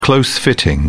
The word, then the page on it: close fitting: British English